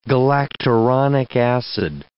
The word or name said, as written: galacturonic acid